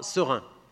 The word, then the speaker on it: Seraing: Une des organisations du FC Metz, c'est qu'un club de Belgique, qui s'appelle, ça ne s'invente pas, Serin. Ça s'écrit S-E-R-A-I-N-G.